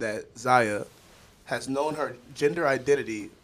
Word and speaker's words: Zaya: Z- did I say that right? Zaya? Yeah, Zaya? Zaya? No idea. Zaya. – Zaya. That's what I'm saying.